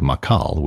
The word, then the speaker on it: Macal: Oh, very nationalistic, Dvorak, as this is conductor Zdeněk Macal, although in the United States he pronounced it Zdeněk Makal, with the Milwaukee Symphony and the polka from Dvorak's Czech Suite.